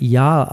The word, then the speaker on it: yazh: Das ist eine Bogenharfe, die YAZH geschrieben wird, aber Yarl ausgesprochen wird und hin und wieder auch mal ein Synth.